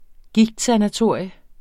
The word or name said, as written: gigtsanatorium